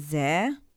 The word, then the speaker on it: Zaire: known as Zaire.